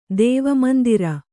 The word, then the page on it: ದೇವಮಂದಿರ: dēva mandira